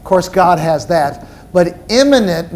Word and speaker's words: immanent: Number two, under attributes of God's nature, God is immanent. Let me spell that for you. It's I-M-M-A-N-E-N-T. Immanent. It's not eminent, E-M-I-E-N-T.